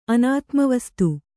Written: ಅನಾತ್ಮವಸ್ತು

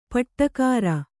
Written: ಪಾಡುಗಾರ

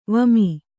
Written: ವಿಮೆ

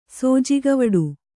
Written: ಸೋಜಿಗವಡು

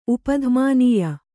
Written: ಉಪಧ್ಮಾನೀಯ

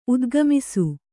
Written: ಉದ್ಗಮಿಸು

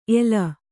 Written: ಏಲ

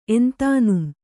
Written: ಎಂತಾನುಂ

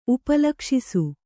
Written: ಉಪಲಕ್ಷಿಸು

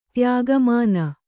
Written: ತ್ಯಾಗಮಾನ

